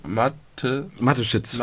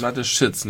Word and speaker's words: Mateschitz: Wie spricht man die aus? – Mateschitz. – Mateschitz, nicht Sitsch.